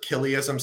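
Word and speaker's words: Chiliasm: And then you can see this trajectory go away from, how do you say Kylism or childism? – They pronounce it Kiliism. – Kiliism. – But they spelled CH, so that's why it's a little okay.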